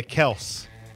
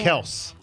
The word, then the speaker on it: Kelce: Yeah, K-E-L-C-E. Yeah. Kels. – Hmm. Well, I don't know. Is the public ever going to jump on board with that? We've been saying their last name wrong the whole time.